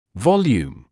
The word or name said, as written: volume